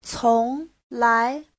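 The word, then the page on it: conglai: cong2lai2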